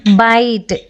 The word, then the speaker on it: bite: ಉದಾಹರಣೆಗೆ ಬೈಟ್ BITE ಅಂದರೆ ಕಚ್ಚು. ಈ ಪದದ ಕೊನೆಯಲ್ಲಿರೋ ಈ ಸೈಲೆಂಟ್ ಆಗಿದ್ರೂ ಅದು ಹಿಂದಿನ ಐನ ಧ್ವನಿನ ದೀರ್ಘಗೊಳಿಸುತ್ತೆ.